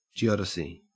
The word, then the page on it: geodesy: Australian English